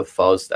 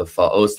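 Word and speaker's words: Faust: فاست، هر دو این تلفظ ها در زبان های مختلف اروپایی هست و ما هم هر کدوم یه جای اروپا هستیم به یک کدومش عادت داریم، هر دو تاش درسته.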